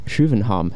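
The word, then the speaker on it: København: or Schuvenham, as they say in Swedish.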